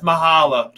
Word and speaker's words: Machala: Yeah, it's Mahala.